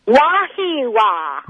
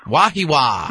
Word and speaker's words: Wahiawa: Wahiwa. – Wahiwa. – How do you spell that? – W-A-H-I-A-W-A.